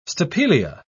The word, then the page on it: stapelia: British English